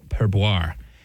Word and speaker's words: Perboyre: I don't really blame you for taking a double take at the name, Sarah, because it's spelled P-E-R-B-O-Y-R-E. Normally, I would think that Y would be an I, but there's a Y there.